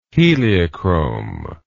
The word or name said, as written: heliochrome